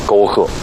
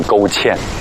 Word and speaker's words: 沟壑: 刚刚把沟壑读成了沟欠